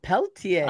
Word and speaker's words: Pelletier: How do I say that? – Pelletier. – Uh-huh. Thank you. Yeah, French-Canadian, right? – Well, the husband is, yes.